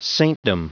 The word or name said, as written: saintdom